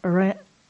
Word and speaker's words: Orionids: Orionids.